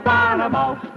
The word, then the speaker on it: Bonomo: You spell it B-O-N-O-M-O, Bonomo, oh-oh, Bonomo, Turkish Taffy.